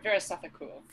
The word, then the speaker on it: Weerasethakul: Ver- Weerasethakul.